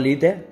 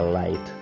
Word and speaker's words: Lite: Digo Lite porque así suena más paleto y ese tío sonaba a paleto, ¿sabes? ya sé que se pronuncia light, pero bueno.